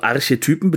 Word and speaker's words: Archetypen: Also Archetypen bedeutet keine vollen Menschen, sondern das sind ganz gewisse Rollen, die hier ausgespielt Betonung werden. Betonung auf Typen.